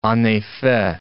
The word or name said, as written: en effet